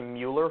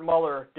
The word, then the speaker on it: Mueller: So it looks like Robert Mueller, and by the way, people tell me it's not pronounced Mueller. I was pronouncing it Mueller for a while because that's how it's fucking spelled, but people are saying, no, it's Mueller, so whatever.